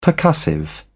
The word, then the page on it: percussive: British English